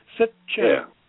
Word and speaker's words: Sitchin: But you spell it just the way you pronounce it. Sitchin.